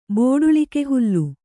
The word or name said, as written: ಬೋಡುಳಿಕೆಹುಲ್ಲು